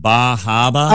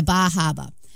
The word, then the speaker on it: Bar Harbor: Isn't that pronounced Bahaba? – Oh, yeah, Bahaba.